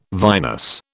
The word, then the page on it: vinous: British English